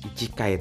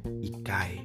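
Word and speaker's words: ikai (一階): Tapi inter lantai 1 bukan ichikai tapi mereka bacanya ikai.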